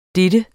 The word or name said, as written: dennes